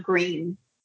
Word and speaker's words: Gruene: The town of Greene, and it's spelled G-R-U-E-N-E.